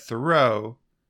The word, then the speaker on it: Thoreau: Okay, they're saying, yeah, they're Amos Bronson Alcott and Thoreau's aunt each wrote that Thoreau is pronounced like the word thorough.